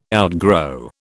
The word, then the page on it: outgrow: British English